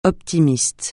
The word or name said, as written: optimiste